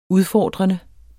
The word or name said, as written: udfordrende